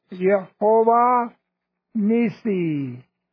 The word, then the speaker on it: Jehovah Nissi: Pronounced Jehovah Nissi, also known as Yahweh Nissi, which is could be said of every one of these with Jehovah in front of it.